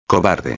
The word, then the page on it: cobarde: espanhol